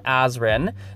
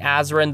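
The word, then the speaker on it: Azren: That is spelled P-O-L-Y-G-L-O-T, A-Z or Z, depending on your country, R-E-N. You can also find me simply by searching Azrin the Language Nerd on whatever your favorite social media platform is. So again, that is spelled A-Z or Z-R-E-N, the Language Nerd.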